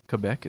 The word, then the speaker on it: Quebec: They arrived in Quebec, if you want to say it like they say it. – It's French. – Quebec, it's French.